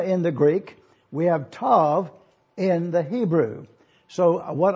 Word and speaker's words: Tav: You will notice also that the last letter of the Hebrew alphabet is Tav, is the way we pronounce it, and you will see that it is presented to us on this diagram that we have for you.